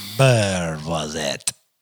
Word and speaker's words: Beurre Noisette: Beurre Noisette.